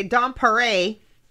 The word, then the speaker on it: Dom Paré: Dom Paré, did I say that right? – Yeah, you said it right.